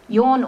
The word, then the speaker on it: Jørn: Jørn Utzon was how Premier Joe Carl explained his name should be pronounced, Y-A-W-N, at the unveiling of the winner.